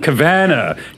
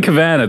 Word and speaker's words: Cavanna: I said that correctly? – Absolutely not. – Absolutely not. – Cavanna. Kevin Cavanna. That's right.